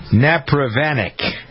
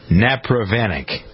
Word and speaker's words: Napravnik: Let's see if I can pronounce this correctly. Rosie Napravanik, I believe, is the correct pronunciation. N-A-P-R-A-V-N-I-K. Rosie Napravanik. Napravanik. Yeah, Napravanik.